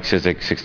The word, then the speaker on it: Czyzyk: Mike Sizek, 908 North Cambridge.